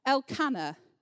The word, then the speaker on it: Elkanah: And Hannah was the wife of, I just have to ask Dan Hoyle how to say this, Elkanah.